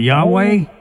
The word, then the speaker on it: Yahweh: You're talking about Yahweh? – That's what I mean. That's the way we say it is Gentiles or whatever. But it's spelled it's spelled YHWH, no vowels.